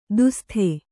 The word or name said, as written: ದುಸ್ಥೆ